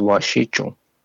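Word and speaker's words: wasichu: And the pronunciation of that is, so the first part is like wash, okay. W-A-S-H, so wash. So, but that C is a J sound, so it's washichu.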